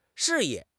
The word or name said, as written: 示意